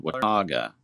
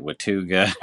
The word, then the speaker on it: Watauga: There was one in a, there's a little community called Watauga that's, and a lot of times people mispronounce it Watuga or something like that, but I believe it was a Cherokee word that meant water, but there's a lake there.